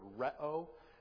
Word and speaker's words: rheo: If you want to look it up, flow, the word for flow is the word reo. We would spell it R-H-E-O.